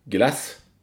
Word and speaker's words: glas: Men glas på danska uttalas glass.